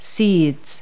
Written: seeds

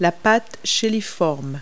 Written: cheliped

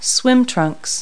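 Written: swim trunks

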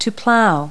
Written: plow

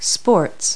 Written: sports

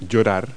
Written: cry

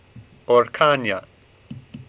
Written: Orcagna

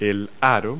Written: rim

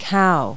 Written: cow

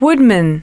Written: woodman

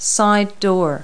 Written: side door

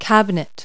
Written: cabinet

